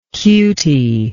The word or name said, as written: cutey